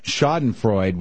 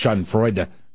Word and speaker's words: Schadenfreude: Your feelings of your use of the German expression Schadenfreude, which someone had to point out to us, I think, is pronounced Schadenfreude.